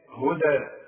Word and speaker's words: هدى: على هدى اذا وقفت هي برضو ياء تكتب بالياء. وهكذا كتب القرآن وهكذا يقرأ انا اقراها بالالف هدى واخد بالك.